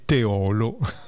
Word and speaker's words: Teolo: La pronuncia corretta di Teolo, comune in provincia di Padova, è Teolo, con l'accento che cade sulla O chiusa, ovvero sulla penultima sillaba.